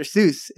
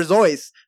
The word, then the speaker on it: Seuss: Dr. Seuss is not pronounced Dr. Seuss. It's pronounced Dr. Voice or Zois. Sorry, I don't know why I said it. It rhymes with voice. Zois.